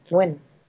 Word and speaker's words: Nguyen: I'm just say Nguyen and add an N in front. And that's pretty much how you pronounce it, Nguyen. – Yep, Nguyen.